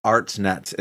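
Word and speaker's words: Hartnett: Daddy Artnet is in it. I'm not going to pronounce the H in his name, I believe.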